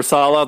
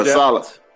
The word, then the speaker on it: Saleh: Oh, Robert Saleh, the new coach. – Yeah, Saleh.